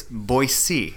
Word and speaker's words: Boise: But for the most part, we have learned, and we even asked some of the locals, it's Boise. And they have t-shirts and other things, magnets to remind people it's pronounced B-O-Y-C, or S-E-E, essentially, Boise. So we've been saying it wrong all along.